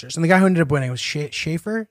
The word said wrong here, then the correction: Scheffler: Is that how you say it? – Scotty Scheffler.